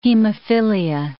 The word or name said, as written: hemophilia